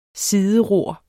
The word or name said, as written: sideror